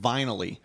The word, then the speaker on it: Vinyl-y: The service, or the company rather, is called Vinyl-y, which rhymes with finally.